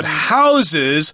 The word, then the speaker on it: houses: Okay, so it houses Dutch art. Hear the word house with a z sound at the end there.